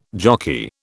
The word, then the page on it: jockey: British English